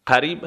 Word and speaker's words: قریب: قریب هم که به معنای نزدیک هست. ق به صورت حرف گ تلفظ میشه در زبان عربی عراقی.